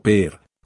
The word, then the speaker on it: Pierre: alquilé un coche para conducir hasta la ciudad capital de Pierre, o Pierre, como la pronuncian allí.